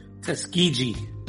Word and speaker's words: Tuskegee: Did I say it correctly? – Tuskegee. – Dang it. I thought I said Tuskegee the last time. – Tuskegee. I mean, you can say it however you want, but it's okay.